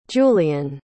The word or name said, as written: julien